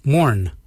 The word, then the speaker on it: moorn: Yunt is spelled Y-O-O-R-N-D. And the last colour is morn. Morn is black. Morn is spelled M-O-O-R-N.